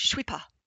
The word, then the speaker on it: je suis pas: In modern French, it's pronounced je suis pas.